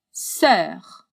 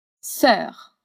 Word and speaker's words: sœur: Sœur. Essa palavra tem uma só sílaba e causa um pouco de dificuldade pelo e dans l'o, literalmente o e dentro do o.